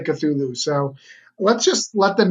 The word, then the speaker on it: Cthulhu: Yep, you pronounced it right. Though I was just in an interview where they did not. They were Sakulu, like they kept saying it very weird.